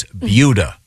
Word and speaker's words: Buda: No, it's pronounced like beauty, so it's Buddha. B-U-D-A.